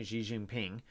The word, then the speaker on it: Xi Jinping: Now, China, Xi Jinping, I think is the way you pronounce his name, Xi Jinping, wants to swallow up Taiwan, Democratic Taiwan.